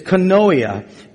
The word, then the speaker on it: koinonia: It's koinonia, which means to hold something in common.